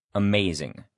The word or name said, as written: amazing